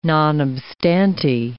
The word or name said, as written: non obstante